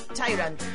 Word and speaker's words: Tyrant: Tyrant o Tyrant. La pronunciaré bien. La nueva es Tyrant.